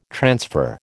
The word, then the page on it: transfer: American English